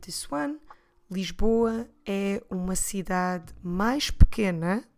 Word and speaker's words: cidade: The word for city is cidade. Como se escreve cidade? C-I-D-A-D-E.